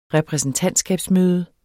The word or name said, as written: repræsentantskabsmøde